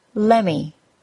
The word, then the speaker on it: lemme: Let's start with the most well-known, lemme. If you see this spelled, which you usually won't, it's spelled L-E-M-M-E, and is actually the words let plus me, lemme. As in, lemme help you with that. Wanna. W-A-N-N-A.